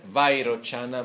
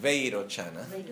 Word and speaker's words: Vairochana: que en verdad Veirochana no es Vairochana, ma es Veirochana. El problema es que en sánscrito se escribe Veirochana, pero cuando llegó en Occidente se escribe la fonética inglesa. En la fonética inglesa se escribe vi, a, i, que nosotros vamos a leer como vai, pero en inglés cómo se va a leer? Ve.